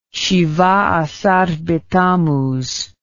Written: shibah asar betammuz

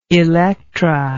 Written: elektra

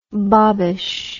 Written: bobbish

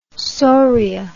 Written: soria

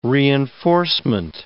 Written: reinforcement

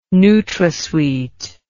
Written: NutraSweet

